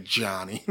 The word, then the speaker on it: Gianni: Like Gianni. Not Johnny, but Gianni.